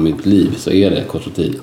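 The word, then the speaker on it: Côte-Rôtie: Det låter alltid lite finare, alltså, när man tar det på ett väldigt franskt uttal. Côte-Rôti.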